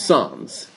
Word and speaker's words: Sens: It's Sans. S-E-N-S, no? – Yeah, I was pronouncing it the way it's spelled. – Right? Sans.